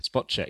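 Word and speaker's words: Spacek: Spotcheck, yes. – Is it Spotcheck? Have I got that right? – I think it's supposed to be pronounced Spotcheck.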